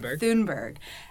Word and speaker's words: Thunberg: Thunberg. I say her last name wrong. – Thunberg.